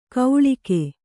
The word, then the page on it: ಗೌಳಿಗೆ: gauḷige; Kannada